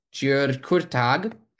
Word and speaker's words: György Kurtág: I've worked on the music of György Kurtág.